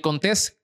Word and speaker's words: Contés: Mi nombre es Miguel Contés, con acento en la E, y nos vemos en la próxima.